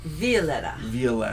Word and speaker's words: Violetta: Violetta. That's the Spanish pronunciation.